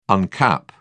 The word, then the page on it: uncap: British English